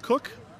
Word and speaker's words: Koch: My Twitter is actually It's Pronounced Cook, but my name is spelled different. So if you're looking me up on Facebook, my last name is spelled K-O-C-H.